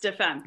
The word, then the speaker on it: Des Femmes: And this magazine is called De Femmes. Am I pronouncing it right? It's French. – Perfect. Yes, De Femmes.